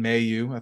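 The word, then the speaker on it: Mailloux: I know that we've always said it wrong. But I'm gonna say Logan Mayu. I think that's the correct pronunciation of it.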